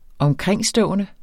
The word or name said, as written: omkringstående